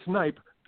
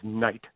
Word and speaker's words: Kneipp: Well, anyway, investigators learned that the name of the victim was Edward Kneipp, and that's spelled K-N-E-I-P. You know, you keep wanting to say it Kneipp, but we know it's Kneipp because at the time people would often mispronounce it as Knight, like a knight of shining armor.